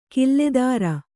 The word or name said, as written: ಖಿಲ್ಲೇದಾರ